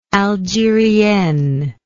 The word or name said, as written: algerienne